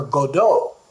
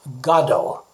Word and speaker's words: Godot: Now, I first learned that as Waiting for Godot, but I found that that's a mispronunciation, that it's kind of an Americanization. The author, who was Irish and lived most of his life in Paris, actually intended it to be pronounced Godot.